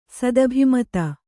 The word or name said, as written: ಸದಭಿಮತ